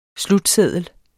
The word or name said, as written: slutseddel